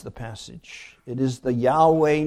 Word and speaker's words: Yahweh: And that's a technical word meaning this word that is usually pronounced Yahweh.